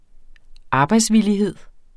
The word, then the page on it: arbejdsvillighed: ˈɑːbɑjds-